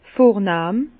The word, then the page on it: vornahm: Bas-Rhin; Herrlisheim